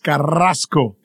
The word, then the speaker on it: Carrazco: I guess, you know, if we say her name the proper way, it's Carrasco, which is always fun to do because there's nothing like being able to roll your R's.